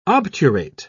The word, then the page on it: obturate: British English